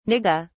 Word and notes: nigga: British English

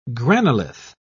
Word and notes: granolith: British English

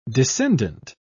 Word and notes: descendent: British English

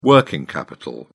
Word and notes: working capital: British English

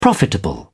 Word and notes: profitable: British English

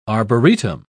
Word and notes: arboretum: British English

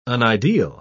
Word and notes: unideal: British English

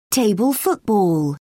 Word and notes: table football: British English

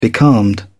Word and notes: becalmed: British English

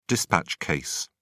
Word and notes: dispatch case: British English

